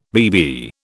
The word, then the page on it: BB: British English